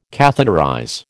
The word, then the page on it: catheterize: American English